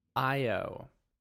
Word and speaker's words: Io: it tells you how to pronounce it, and it has the word I, dash O-H, just in case you were too dense. It's pronounced hey. I-O.